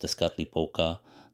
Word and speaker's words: Tezcatlipoca: Tezcatlipoca, pronounced Tezcatlipoca, the night sky god, and the other was Quetzalcoatl, the wind god.